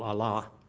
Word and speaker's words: Allah: I guess they put the proper pronunciation as an Allah, but they put the pronunciation on the second syllable, Allah, which means their God, God, that he supposedly received revelations from God, wrote them down.